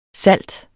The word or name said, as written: salt